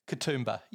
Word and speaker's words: Katoomba: Katoomba, you were pretty close.